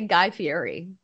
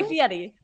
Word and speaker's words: Guy Fieri: I like how you say Guy Fieri. You say it like the problem, like how you're supposed to pronounce it, I assume. – Guy Fieri.